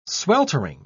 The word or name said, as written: sweltering